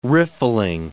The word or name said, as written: riffling